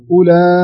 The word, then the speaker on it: أولئك: هذه تعني أن هذه الواو تكتب ولا تنطق، يعني ما يصلح نقول أولئك أولئك، يعني لابد نخطبها خط كأنها مين موجودة، نقول أولى أولى.